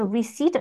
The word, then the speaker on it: receipt: So receipt over here, you see this, like so this comes with a P, but we don't pronounce the P, right?